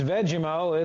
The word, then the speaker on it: Vegemo: It's Vegemo. It's spelled just like it sounds. You don't have to worry about that.